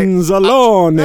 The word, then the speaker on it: Anzalone: First of all, and I should have known this because I speak Italian, the correct pronunciation Oh, I saw that. Our linebacker, right? – Anzalone. – Hey, Anzalone. Anzalone. Right. Hey, right. Forget about it. Who that left you, boss? – Well, I should have known. It means A-E-O-U. That's how you pronounce it in Italian.